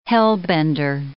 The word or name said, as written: hellbender